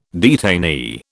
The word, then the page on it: detainee: British English